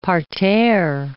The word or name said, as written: parterre